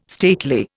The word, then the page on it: stately: Indian